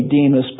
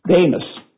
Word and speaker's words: Demas: And you might say Demas, but the Greek letter that's translated by the English E is an eta, so it has a long A sound. Demas.